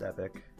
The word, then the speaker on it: epoch: Yeah, it's been referenced a few times, and also the previous era before the Age of Abandonment was the Golden Epoch. Epoch spelled E-P-O-C-H. – Isn't that epoch? – No, it's pronounced epic.